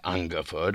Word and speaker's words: Ungerford: The girl pronounced it Ungerford with an accent on the ung.